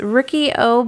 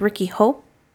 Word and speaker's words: Riki-Oh: It's Ricky O, but actually when they say his name, it's Ricky Ho.